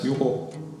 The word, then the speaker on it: Juho: Yeah, it's Juho.